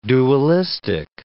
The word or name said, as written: dualistic